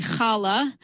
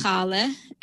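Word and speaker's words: challah: Another example would be chala. In Yiddish, it's chale, and in English, it could be chala or chali.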